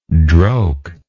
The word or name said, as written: droke